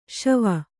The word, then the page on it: ಶೇವ: kannada